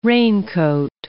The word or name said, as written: raincoat